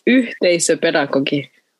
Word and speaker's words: yhteisöpedagogi: Tell us, what is it? – It's yhteisöpedagogi.